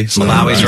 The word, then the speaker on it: Malawi: I think Malawi. – Malawi's right.